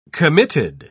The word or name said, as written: committed